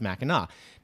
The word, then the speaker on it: Mackinac: It is pronounced Mackinac.